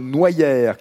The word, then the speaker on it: Noyers: Chers amis, bonjour Comment s'appellent les habitants de Noyères sur Serein. il s'appelle les Nucériens et les Nucériennes, car le nom de Noyère, qui s'écrit en fait noyer, euh, comme l'arbre euh, au pluriel, se prononce Noyère et ce nom vient du latin sans doute, du latin nux, qui signifie la noix.